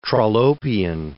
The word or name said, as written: Trollopian